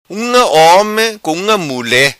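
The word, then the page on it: Unga Homi: Macanese Patuá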